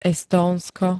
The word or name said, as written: Estónsko